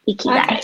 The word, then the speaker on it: Ikigai: Yeah, it is Ikigai. Ikigai.